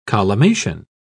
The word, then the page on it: collimation: British English